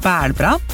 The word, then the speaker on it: bælbra: Den må du gjenta. – Bælbra. – Bær? – Bælbra. – Bær? – Nei, bælbra. – Det klarer ikke jeg å uttale. Ta det rolig. – Bælbra. – Bælbra. – B-A-L, og så bra. – B-A-L. – Nei, B-A.